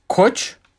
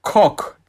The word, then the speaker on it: Koç: soyadım da Koç ve K harfiyle yazıldığı için Kok diye telaffuz ediyorlar.